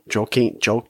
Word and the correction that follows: Joaquin: Joaquin Phoenix.